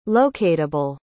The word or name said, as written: locatable